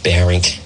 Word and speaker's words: Barrington: So one time, I'm on the radio, and I'm saying, oh, so-and-so in Borington. And someone who's a total nitwit comes in and says, I hate to interfere, but it's pronounced Barrington.